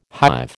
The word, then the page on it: hive: American English